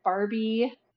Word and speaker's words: Barbie: That is Barbie, like spelled like the doll. Martins, M-A-R-T-E-N-S, and last name Dangond, D-A-N-G-O-N-D, and on Instagram, I am B Dangond.